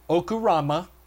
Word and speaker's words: ochuroma: It's O-C-H-U-R-O-M-A, Okurama, okay? O-C-H-U-R-O-M-A, excuse me.